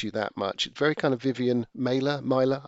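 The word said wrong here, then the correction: Maier: I never know how to pronounce her name. – Vivian Meyer. – Maya, thank you.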